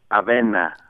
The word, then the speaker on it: Avena: Yeah, it's Avena. – Avena. Avena. Yes. So let's listen. – Avena, avena, avena, la avena siempre es buena. Con un poco de fruta, con fruta y con almendra, la avena siempre es buena.